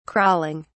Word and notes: crawling: British English

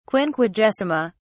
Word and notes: quinquagesima: British English

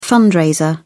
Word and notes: fundraiser: British English

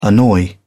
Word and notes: annoy: British English

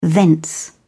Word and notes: thence: British English

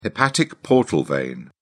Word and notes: hepatic portal vein: British English

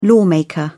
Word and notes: lawmaker: British English

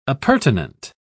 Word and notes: appurtenant: British English

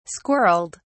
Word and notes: squirrelled: British English

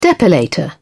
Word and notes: depilator: British English

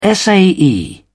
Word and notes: sae: British English

